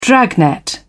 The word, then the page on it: dragnet: British English